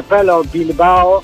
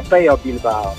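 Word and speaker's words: Pello Bilbao: Nie znam hiszpańskiego, ale wiem, że jednak L w hiszpańskim czyta się jako J, więc nie Pelo Bilbao, tylko Peyo Bilbao.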